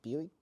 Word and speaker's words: Biuk: Stipe Bjuk. It's a S-T-I-P-E. – Stipe. – That's his first Bjuk. – Name. And his last name is B-I-U-K. So Bjuk.